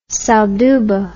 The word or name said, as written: salduba